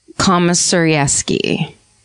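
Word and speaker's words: Komisarjevsky: and they're spotted by a recent parolee named Joshua Komisarewski, is basically how you pronounce that last name.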